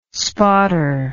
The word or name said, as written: spotter